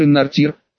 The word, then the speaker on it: Иннартир: Мы-то называли монарха Арканера так, но на самом деле он Иннартир, с ударением на последнем слоге.